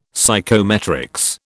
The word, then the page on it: psychometrics: British English